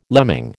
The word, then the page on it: lemming: American English